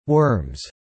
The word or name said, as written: worms